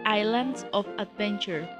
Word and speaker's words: Islands of Adventure: Entonces, el parque del que nos queda pendiente hablar es de Islands of Adventure.